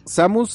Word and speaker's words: Samus: Samus, Samus.